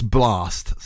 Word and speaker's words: BLXSD: And that's Blast, spelt B-L-X-S-D.